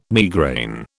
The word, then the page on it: migraine: British English